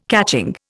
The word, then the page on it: catching: Indian